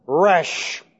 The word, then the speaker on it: Resh: This is pronounced Resh.